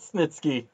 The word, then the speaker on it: Snitsky: Good old Snitsky. – You finally said it right.